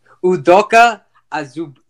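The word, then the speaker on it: Udoka Azubuike: Okay, now moving on to Odoko Azubike. – Okay, we butchered the guy's name. Sorry. – Okay, hold on. – Try it again. – Okay, bro. Udoka Azubike.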